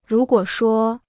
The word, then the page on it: 如果说: rú guǒ shuō